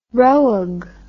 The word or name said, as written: roeg